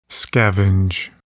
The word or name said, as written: scavenge